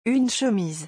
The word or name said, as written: une chemise